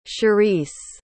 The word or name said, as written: cherise